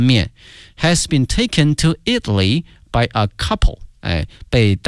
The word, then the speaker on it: Italy: 意大利这个词啊，同学们要小心了，它的名词和它的形容词呢，这个发音同学们一定要小心了。意大利呢是 Italy 啊，国名。如果说意大利的或意大利人叫 Italian，哎，所以说这个发音还是有区别的。 Italy, Italian.